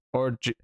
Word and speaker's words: Orji: Orji. It's O-R-J-I.